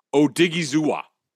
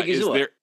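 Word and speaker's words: Odighizuwa: The other guy, who, by the way, is now what I just figured out how you say his name, and it's now one of my favorite names in the league. Osa Odigizua. – Oh, yeah, Odigizua. – Odigizua. Yeah.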